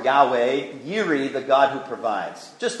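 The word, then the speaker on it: Yiri (יִרְאֶה): Yahweh Yiri, oftentimes in our English we pronounce it as Jireh, although the Hebrew is truly Yiri.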